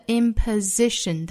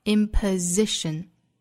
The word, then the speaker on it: imposition: 末尾是清辅音 s， false，而美音开口度会更大更松弛， false，后面的 imposition 单词内不含有字母 r，不要带出卷舌音来，应该读成 imposition，注意字母 s 对应浊音 z， imposition。